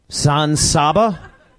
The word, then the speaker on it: San Saba: He's not in San Sabo? – San Saba? No. – Oh, Alec Baldwin not wrong in San Saba. For a whole week now, I've been pronouncing it wrong.